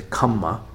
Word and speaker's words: kamma: Anyway, so in the Pali pronunciation is kamma, and then in Sanskrit is karma. So sometimes in these quotes, you're going to find that it's spelled as K-A-M-M-A, just so you know.